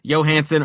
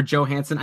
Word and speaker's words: Johansson: So maybe add Marcus Johansson or Johansson. I know that Ryan Johansson has said it's specifically a hard J.